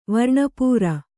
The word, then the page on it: ವರ್ಣಪೂರ: varṇa pūra